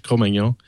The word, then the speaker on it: Cro-Magnon: One audiobook that you might consider downloading for free is, I always pronounce it incorrectly, but Cro-Magnon. I pronounce it as the French, Cro-Magnon.